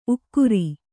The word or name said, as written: ಉಗುರ್